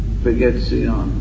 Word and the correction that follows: Spiegazione: Spaghetti on. What is it? How do you say it? I don't. How do you say it? Purport. – Spiegazione. – Spiegazione. – Spiegazione. – Huh? Spiegazione. – Spiegazione. Spiegazione. – That's what I said.